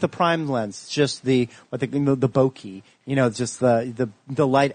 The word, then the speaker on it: bokeh: Is that called bokeh? – Bokeh, yeah. – How do you spell that? – B-O-K-E-H. It's a Japanese term.